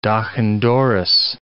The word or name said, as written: doch-an-doris